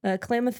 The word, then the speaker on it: Klamath: He grew up in Klamath, hope I'm saying that right, Falls. – Yeah. – Klamath Falls,